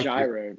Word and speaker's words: gyro: It's pronounced gyro, bro.